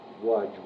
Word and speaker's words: Łódź: Łódź, which is spelled L-O-D-Z. – Uh-huh. – But the L has a line through it, so it's pronounced like a W.